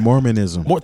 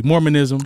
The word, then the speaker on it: Mormonism: Mormonism. – Thank you. Mormonism.